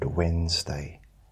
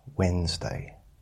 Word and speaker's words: Wednesday: I think I struggled when I was at school with the word Wednesday, because it's spelled Wednesday. And that didn't make sense to me. Wednesday.